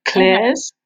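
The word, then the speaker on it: Klairs: And the vitamin C serum I use is from a brand called Dare Clairs, or Clairs, is it? It's spelled with a K. So it's K-L-A-I-R-S.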